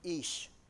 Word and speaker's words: ish: If you study it in the Bible, the word man, the Hebrew word for it is ish, or ish, to pronounce it correctly.